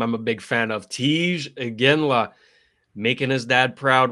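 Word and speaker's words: Tij: And you pronounced it well, very well there. – Tiige. – Yeah, Tiige.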